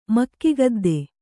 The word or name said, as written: ಮಕ್ಕಿಗದ್ದೆ